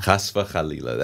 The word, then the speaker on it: Chas v'chalila: Chas v'chalila. – Chas v'chalila. That's how you say it in Hebrew.